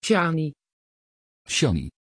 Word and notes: chani: Dutch

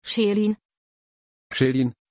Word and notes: Shalyn: German

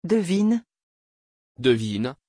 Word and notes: devyn: French

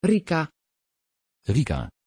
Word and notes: Rica: Dutch

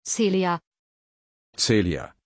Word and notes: celia: German

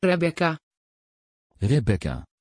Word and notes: rebecca: Dutch

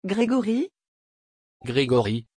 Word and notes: grégory: French

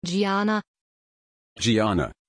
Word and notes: giana: German

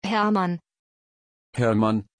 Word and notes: herrmann: German

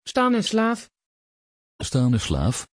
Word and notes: Stanislav: Dutch

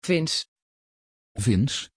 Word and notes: vinz: Dutch

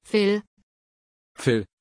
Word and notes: phill: German

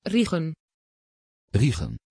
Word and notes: reagan: Dutch

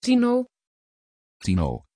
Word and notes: tino: Dutch

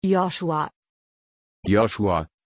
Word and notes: Yoschua: German

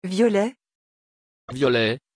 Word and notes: violet: French

